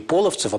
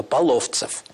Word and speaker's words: Половцев: Кстати, у Шолохова ударение написано не Половцев, а Половцев. то это обусловило и сюжет, и композицию первой части.